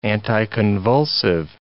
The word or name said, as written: anticonvulsive